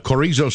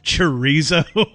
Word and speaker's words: chorizo: I believe that's pronounced chorizo. – Chorizo, that's what I said. Chorizo. – That is not what you said. What did I say then? – You can hear it played back on the podcast. – All right, fine. I mispronounced it.